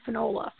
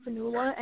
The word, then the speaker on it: Fionnuala: So the main character bothers me, that woman, Fionnuala. First, her name bothers me a lot because in Ireland we pronounce that name Fionnuala, and in my brain I'm always correcting her, Fionnuala.